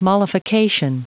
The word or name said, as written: mollification